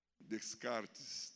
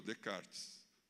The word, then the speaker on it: Descartes: Descartes, não, descartado. Melhorou, Descartes, mas também não foi ele. A pronúncia melhorou, mas a autoria não.